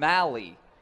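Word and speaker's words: Mahle: Kraig Riley and Jack Zduriencik getting you ready for tonight's 7:05 first pitch between Jordan Lyles and Tyler Molly, who I believe I've been pronouncing wrong. Mally. They even wrote it in the thing. Mally like Rally.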